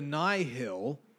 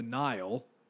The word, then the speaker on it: Nihil: We're going to talk about rewrites here for a second, because apparently we've been pronouncing everything wrong, and I'll be honest, it grated at me so hard in this book. So the Nihil had been called the Nile, which nihilist, Nile, okay.